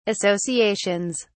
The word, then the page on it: associations: British English